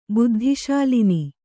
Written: ಬುದ್ಧಿಶಾಲಿನಿ

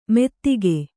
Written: ಮೆತ್ತಿಗೆ

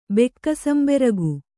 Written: ಬೆಕ್ಕಸಂಬೆರಗು

